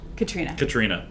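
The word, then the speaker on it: Caitriona: But, no, I mean, I thought, is it Katriona? – Katrina. – I'm very, like... – Well, it's spelled, that's how yeah. it's spelled. – You're Katrina right.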